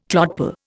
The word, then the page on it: clodpoll: Indian